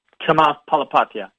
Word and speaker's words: Chamath Palihapitiya: You know how to pronounce it. – Chamath Palihapitiya. – Yes, okay.